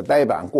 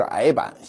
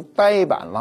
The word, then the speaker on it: 呆板: 这个呆板过去我们都说是矮板，现在读音都改了，统一成呆板了。